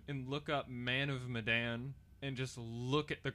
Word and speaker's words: Medan: It's spelled M-E-D-A-N for Medan. – Man of Madden.